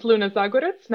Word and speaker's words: Luna Zagorac: My name is Luna Zagorac. Not everybody gets that pronunciation correctly, but you did a great job.